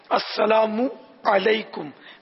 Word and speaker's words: السلام علیکم: السلام علیکم میم پر پیش بھی پڑھنا ہے، السلام علیکم ورحمۃ اللہ وبرکاتہ یا وبرکاتہ.